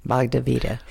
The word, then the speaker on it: Bhagavad Gita: Indians have the Bhagavad Gita. I think I always mispronounce that one. – Bhagavad Gita.